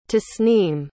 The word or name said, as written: tasneem